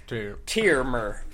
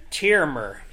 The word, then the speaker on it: Tiymir: My name is Tiermer. – Say it again. – Tiermer. – Tiermer? – Tiermer. – How do you spell that, Tiermer? – T-I-Y-M-I-R.